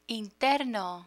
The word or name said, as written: interno